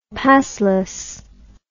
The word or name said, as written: passless